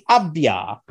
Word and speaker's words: Avdija: Denny Avdija.